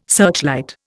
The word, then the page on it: searchlight: Indian